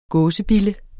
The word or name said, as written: gåsebille